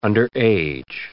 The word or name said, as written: underage